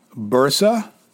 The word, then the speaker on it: Bursa: Bursa, Turkey. That is spelled B-U-R-S-A.